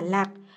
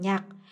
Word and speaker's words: Lạc: Nhớ lại hôm đó khi giảng Đại Bát Niết Bàn Kinh Chú Giải, quả thực đã đọc sai, lẽ ra phải đọc là Lạc. Ông ta lại sơ ý đọc thành Nhạc, đúng là lầm lỗi lớn.